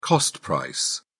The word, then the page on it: cost-price: British English